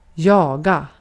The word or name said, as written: kovalamak